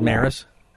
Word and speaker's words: Maris: Is it Maris? – Maris, yeah. – Okay, good. It's like Roger Maris? – Yes.